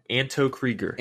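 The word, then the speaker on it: Anto Kreegyr: Anton Krieger? – Anto Krieger. – Anto Krieger. Dang, I was like off by a syllable.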